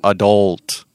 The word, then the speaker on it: adult: Adult. Adult. So think of 헐 And you go adult. adult. There you go.